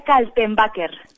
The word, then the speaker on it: Kaltenbaker: Oye, he dicho bien el nombre, María Cantelbaker. – Te iba a decir, sí, María Kaltenbaker. – Kaltenbaker, así, tal cual, se escribe.